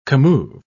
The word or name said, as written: commove